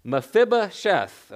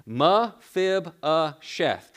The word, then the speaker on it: Mephibosheth: Mephibosheth, okay? You pronounce that. I got the pronunciation on the screen. Mephibosheth.